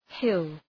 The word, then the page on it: hill: hıl